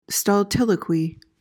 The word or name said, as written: stultiloquy